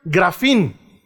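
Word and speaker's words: grafín (γράφειν): En griego, la palabra escribir se pronuncia grafín.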